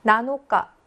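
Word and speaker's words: 나노카 (七日): 나노카라고 읽는데 여기는 며칠을 읽는 거야.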